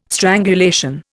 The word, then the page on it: strangulation: Indian